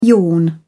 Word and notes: yawn: British English

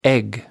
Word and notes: egg: American English

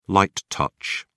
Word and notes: light touch: British English

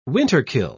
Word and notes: winterkill: British English

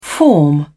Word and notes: form: British English

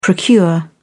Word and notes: procure: British English

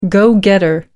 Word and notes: go-getter: American English

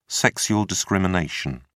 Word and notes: sexual discrimination: British English

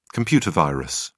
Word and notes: computer virus: British English

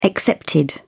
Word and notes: excepted: British English

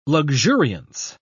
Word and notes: luxuriance: British English

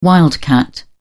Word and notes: wildcat: British English

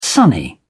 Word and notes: sunny: British English

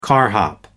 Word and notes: carhop: American English

